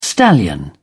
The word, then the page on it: stallion: British English